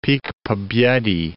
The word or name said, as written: Pik Pobedy